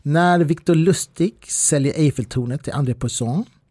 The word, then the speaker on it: Victor Lustig: Man skulle kunna säga att Victor Lustig, som man ska uttala det då egentligen, var på piken av sin karriär när han säljer Eiffeltornet.